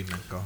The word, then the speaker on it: Menko: Is because I how do you spell it? – M-E-N-K-O. – Yeah, it'd be Minko.